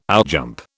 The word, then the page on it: outjump: British English